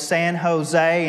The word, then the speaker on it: San Jose: And he kind of let it slide, and he said, now, fellas, you probably should know that out there, they don't pronounce those things that way. They make their J's into an H sound, so it's San Jose and San Juan.